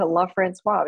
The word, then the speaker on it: LaFrancois: And, you know, my husband's family name, and we said LaFrancois for many years. And over the last several years, we decided to change it to LaFrancois because it is spelled that way, and it really is should be pronounced that way.